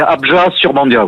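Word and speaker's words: Abjat-sur-Bandiat: Et est-ce que vous, c'est bien Abja sur Bandia, c'est comme ça qu'on doit prononcer? – Alors oui, on ne prononce pas forcément le T final, c'est Abja sur Bandia, oui. – Abja sur Bandia, un village du Périgord, combien il y a d'habitants dans votre village? – 640 habitants.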